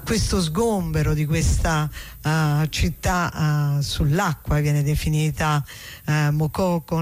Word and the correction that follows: Makoko: Makoko, non so se è con l'accento finale alla francese, Makoko, però probabilmente siccome quello è un paese anglofono sarà in maniera diversa.